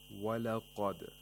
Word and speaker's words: وَلَقَدْ: The qaf has tafkhim because it's a letter of istirla. We have to pronounce it with the mouth opening vertically. وَلَقَدْ قَه قَه. وَلَقَدْ. The dal has qalqala, so we have to make sure that the sound is released from its place of articulation and it's not trapped in the place of articulation of dal.